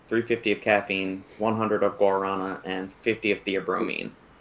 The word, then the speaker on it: guarana: Yeah, I do it like a G-E-R, like guarana. – Guarana. – No, I just don't pronounce the U.